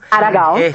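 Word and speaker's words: Aragão: Espero haberlo pronunciado bien, Aragao, ¿no? – Aragao. – Correcto, este músico brasileño, cantante.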